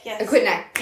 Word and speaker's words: Aquidneck: Because we were on, it's called like, I want to say it right. Aquidneck. – Aquidneck, yes.